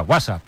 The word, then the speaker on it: WhatsApp: No, peor aún, con acento en la primera. WhatsApp, ¿no? – WhatsApp.